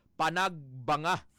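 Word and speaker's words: Panagbenga: Panagbanga Festival kada Pebrero. Odong iya sing spelling is Panagbinga pero ang pronunciation sang mga igurot dito Panagbanga.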